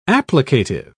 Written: applicative

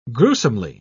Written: gruesomely

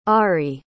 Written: ari

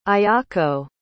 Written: ayako

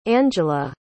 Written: angella

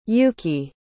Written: Yuki

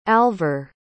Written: alver

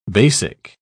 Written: basic

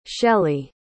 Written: shellie

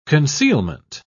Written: concealment

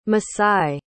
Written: masai

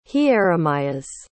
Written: hieremias

